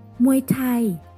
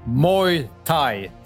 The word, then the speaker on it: Muay Thai: The Muay is pronounced like boy, but with an M instead of B. The Thai pronounced the same way as Thai, as in tie your shoes. Muay Thai.